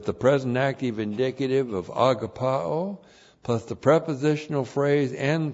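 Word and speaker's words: agapaō: We saw it in 1 John, we saw it in 2 John, we saw it in 3 John, agapao. A-G-A-P-A long O. Agapao.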